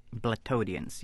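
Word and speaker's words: blattodean: Now, one thing I wanted to ask you about were these organisms called blattodines. – Blattodians, yes.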